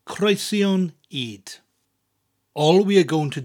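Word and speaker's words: creision ŷd: Cornflakes in Welsh is croesion id. Two words, croesion, spelled C-R-E-I-S-I-O-N, and id, which is spelled Y-D, and the Y has a little accent on it, an upside down V, creating an elongated E sound.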